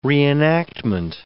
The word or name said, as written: reenactment